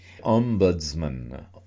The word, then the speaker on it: Ombudsman: Ombudsman, is that pronounced correctly, and what is... – It is pronounced correctly.